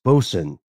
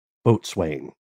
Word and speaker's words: boatswain: and said it's pronounced bosun, not boatswain.